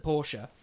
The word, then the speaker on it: Porsche: Back to the mentalists at Porsche. So, they... Notice he's saying it properly.